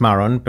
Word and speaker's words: Marrón: Se escribe Mar, es Marrón, pero es, marrón como el color, se escribe el apellido.